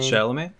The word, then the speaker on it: Chalamet: Timothy, how do you pronounce his last name? – Chalamet. – Chalamet, and he's another great actor of this current generation.